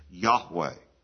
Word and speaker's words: Yahweh: It's interesting, in the Hebrew, we sometimes translate some people would insert the name Jehovah, but Jehovah, there's no J sound in the Hebrew alphabet. the closest we would come to understanding how that name is pronounced is Yahweh. Y-A-H-W-E-H.